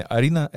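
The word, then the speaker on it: harina: Для тех терпеливых, кто ждал, почему Арина мука, дело в том, что по-испански Арина пишется через H, дословно Харина, но произношение Арина — это мука.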